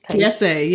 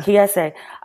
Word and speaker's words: Kiese: I was also thinking about, I'm going to mispronounce his name, Kiese? – Kiese, yeah. – Kiese.